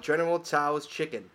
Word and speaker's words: General Tso's chicken: And where would the world be without General Tso's chicken? Silent T for the win.